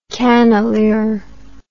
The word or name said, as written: cannelure